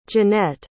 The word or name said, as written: Jenette